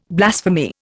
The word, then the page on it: blasphemy: Indian